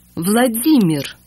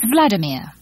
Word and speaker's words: Vladimir: One of the commonest Russian male names is Vladimir. Vladimir. – This name is often mispronounced by foreigners as Vladimir, which is as bad as pronouncing Belinda as Belinda.